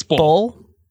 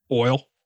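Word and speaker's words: Bole: How do you pronounce that? – It's Bull. – What did I say? – Boil. – Bully. – Boil? – You said boil.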